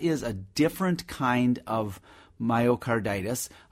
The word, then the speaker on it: myocarditis: All right, another texter writes about a giant cell, and I'm not going to say it right, myocarditis? – Myocarditis, yeah, that's not common.